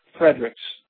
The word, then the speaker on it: Fredericks: It's Sergeant Nathan Fredericks. Let me spell that for you. That's N-A-T-H-A-N. Last name is F-R-E-D-E-R-I-C-K-S.